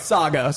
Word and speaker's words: Saga: doesn't rhyme with Sega. I thought it did. It doesn't. I tried to make it rhyme. – He's been name-dropping Saga, and he was trying to be coy, well, coy about it. – You call it... – I know. I was like, it rhymes with a certain Genesis system. No, that's Sega. No, that doesn't rhyme.